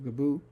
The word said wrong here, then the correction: Gebouw: I don't know. Let's find out what the correct pronunciation of that is. Okay. Translate Google. Let's detect language and then say Gebou. Building. It's Dutch, Gebouw. – Gebouw. – Gebouw. – Gebouw. Okay. Gebouw.